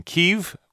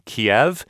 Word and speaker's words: Kyiv: So it was actually in 2006, the State Department adopted that pronunciation and a different spelling, K-Y-I-V, rather than K-I-E-V, because it sounds more Ukrainian rather than Russian.